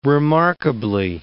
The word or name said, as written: remarkably